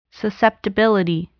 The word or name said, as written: susceptibility